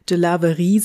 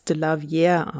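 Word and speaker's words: DeLarverie: Also Stormy Delavierre, streng französisch ausgesprochen, müsste man eigentlich Delaverie sagen. Sie selbst und auch die Medien sprechen aber den Nachnamen immer als Delavierre aus.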